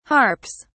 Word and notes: harps: British English

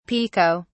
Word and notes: pico: British English

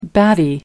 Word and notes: batty: American English

